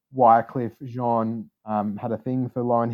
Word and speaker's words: Wyclef Jean: I think it's Wyclef Jean. – Jean, sorry, yeah. Wyclef Jean.